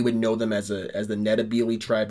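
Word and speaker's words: Ndebele: called them the Matabele, and it's still called Matabele land now, but the way you actually pronounce it is more with an N. N-D-E-L-E-L-E. Nitabele. N-D-E-L-E. I can't spell it. Nitabele. B-E-L-E. N-D-B-E-L-E.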